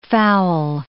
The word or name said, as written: foul